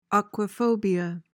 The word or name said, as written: aquaphobia